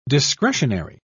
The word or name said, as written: discretionary